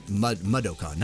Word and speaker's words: Mudokon: Whether he is ready for it or not, the entire fate of the Muktagon species rests on Abe's actions. Mudokon, not Muktagon. I said that wrong there.